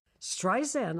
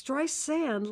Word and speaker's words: Streisand: Streisand, I said. My name isn't with a Z. It's Streisand, like sand on the beach. Now, how simple can you get? Sand on the beach, Streisand.